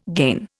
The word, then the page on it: gain: Indian